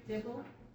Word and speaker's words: Dibble: Lori Dibble. D-I-B-B-L-E.